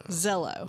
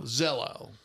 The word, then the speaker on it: Zello: It's called Zulo. Z-U, let me spell it for you. – Zello. – Zello. – I call Not Zulu.